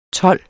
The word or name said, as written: TOLDENES